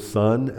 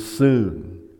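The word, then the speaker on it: soon (συν): The prefix looks, if we were to say it in English, to read it in English, looks like the word sun, S-U-N. But it's actually the word soon, okay? That's how you pronounce it.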